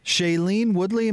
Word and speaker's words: Shailene Woodley: You follow closely Aaron Rodgers' love life, but his current flame, Shailene Woodley.